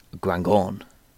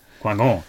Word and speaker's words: Guingamp: I believe it's pronounced Guingamp. – Guingamp.